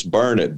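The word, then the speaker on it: Burnet: Well, actually, it's Burnett, darn it. Oh. If you want to say it right, Peggy. – We mispronounce every Texas name. We still can't get New Braunfels right. – Yeah, it took me a long time. You know, there's a saying, it's Burnett, darn it, learn it.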